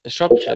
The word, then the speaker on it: Shropshire: Am I pronouncing that right? Shropshire? – Shropshire, that's right.